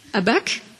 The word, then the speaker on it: Habakkuk: Et on va aller creuser, si tu peux prendre Chloé en Habakkuk, j'ai bien prononcé, Habakkuk.